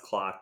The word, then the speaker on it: Kloc: So it's pronounced Kloc, but it's spelled wrong.